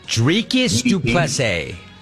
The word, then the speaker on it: Dricus du Plessis: How do you say his name? – Dricus du Plessis.